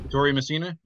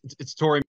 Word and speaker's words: Ettore Messina: Ettore Messina. – Ettore Messina? Yeah, you said it right.